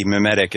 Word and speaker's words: memetic: M-E-M-E-T-I-C, mimetic. It's related to memes.